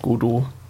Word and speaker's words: Godot: Natürlich spricht man's Godot.